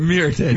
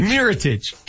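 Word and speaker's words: Mirotić: Mirotic. – Mirotic.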